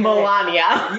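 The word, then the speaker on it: Malanya: Melania.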